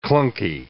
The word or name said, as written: clunky